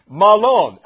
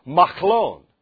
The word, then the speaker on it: Mahlon: Their sons were named Mahlon. Actually, it's Mahlon. Can you say Mahlon? Mahlon is what it looks like, but Mahlon.